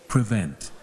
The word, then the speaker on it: prevent: Prevent. – One more time. – Prevent. – Your turn. Prevent. One, two, go. – Prevent. – So you stress on first or second syllable? First or second? Second